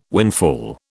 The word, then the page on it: windfall: British English